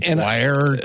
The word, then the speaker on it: coir: C-O-I-R. – Yes. – And nobody knows how to pronounce it. It's a choir, I've heard it pronounced many different ways.